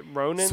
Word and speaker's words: Ronan: Oh, Roman, or why can't I say her name? I have no idea how to say it. – Ronan? – Sarcy Ronan.